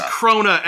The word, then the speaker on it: Krona: And in the meantime, Korna transfers his power to the twins, who he realizes are girls. – Is it Korna or Krona? – It's Krona, and I've been saying it wrong the whole time, but we're not going to re-record that.